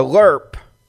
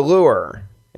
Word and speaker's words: lure: To lerp, to lure is the correct way to say the word, but apparently he screwed it up the first time.